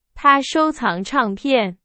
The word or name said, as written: 唱片